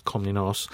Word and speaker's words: Komnenos: She belonged to the Komnenos dynasty, or if you pronounce that correctly, it's Komnenos.